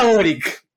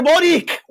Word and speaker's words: Boric: Eh, porque no se dice Boric, weón. no, es culpa mía que el weón no le haya puesto un H al final de su apellido.